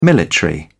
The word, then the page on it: military: British English